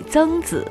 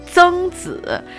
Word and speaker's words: 曾子: So, Zengzi, it's hard to pronounce, and I think we don't have the pronunciation Zeng in English.